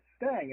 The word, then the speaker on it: Stange: And I said, okay, well, it's Stang.